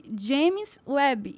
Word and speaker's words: James Webb: E hoje vamos falar sobre James Webb e suas imagens impressionantes. Eu falei certo o nome? – Falou, falou sim.